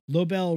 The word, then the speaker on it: Lobel: Lobel rhymes with Nobel, the Nobel Peace Prize.